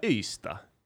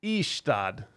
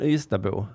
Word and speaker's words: Ystad: De kan inte ens uttala Ystad. Ystad, säger de.